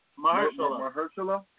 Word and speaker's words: Mahershala: I hope, I don't pronounce this guy's name wrong, but I think his name is pronounced Marshala Ali. Can you let me know Mahershala.